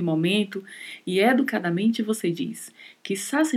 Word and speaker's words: quizás: E muita atenção aqui com a pronúncia, porque a letra Z, a letra Z, não tem som de Z no espanhol, ela tem som de S. Então falamos quizás.